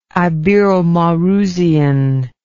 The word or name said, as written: iberomaurusian